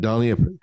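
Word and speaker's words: Dhani: Donnie, thank you for that.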